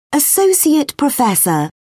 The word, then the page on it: associate professor: British English